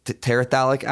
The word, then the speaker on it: terephthalic: So the polyester is monoethylene glycol, and correct me if I'm pronouncing this wrong, terephthalic acid.